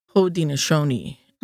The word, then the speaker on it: Haudenosaunee: But today, the people refer to themselves in our own language, which is pronounced Haudenosaunee. And there are actually approximately six different spellings for that in the six different languages.